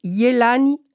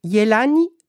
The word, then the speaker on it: Yelany: Je m'appelle Yelani Rivera. Yelani, c'est mon prénom. Ça s'écrit Y-E-L-A-N-Y. Rivera, ça s'écrit R-I-V comme Victor, E-R-A.